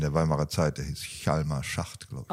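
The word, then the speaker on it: Hjalmar: Chalmar Söderberg. – Chalmer. – Chalmer, habe ich wieder falsch ausgesprochen.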